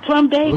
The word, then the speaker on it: Plumbago: pronounce that? Plumbago? – Plumbago, like a plum.